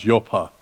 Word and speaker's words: Joppa: He told them everything that had happened and sent them off to, it's pronounced Joppa.